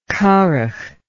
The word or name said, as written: curagh